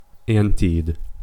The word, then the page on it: anted: American English